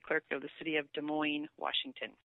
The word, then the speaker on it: Des Moines: So that says location, and then under that says Des Moines, spelled out D-E-S-M-O-I-N-E-S.